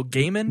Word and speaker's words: Gaiman: Neil Gaiman.